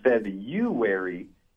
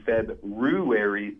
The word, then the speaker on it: February: Well, I was just going to say that actually the pronunciation for February can go both ways. The most common pronunciation in the United States is February, but the less common February is also an acceptable pronunciation. So technically, both of these guys are right in their pronunciation. It's more of which they like to use. – There we go. – Okay. I mean, I feel like just because most people mispronounce it, it doesn't mean it's right, though. – Well, Merriam-Webster identifies both as being correct.